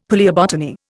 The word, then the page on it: palaeobotany: Indian